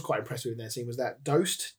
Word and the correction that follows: Dest: I don't know how you pronounce it, the right back. – Oh, Dest. – Dest, yeah, I say, yeah.